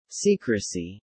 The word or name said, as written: secrecy